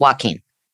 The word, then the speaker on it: Joaquin: And whether you call him Joaquin or Joaquin. – I didn't call him Joaquin. I called him Joaquin. That's what I called him, but it's Joaquin.